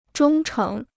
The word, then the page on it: 忠诚: zhōng chéng